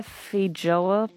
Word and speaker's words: feijoa: Someone calling in about their feijoa plant, if I... Am I pronouncing that correctly? Am I getting it? Yes, that's good.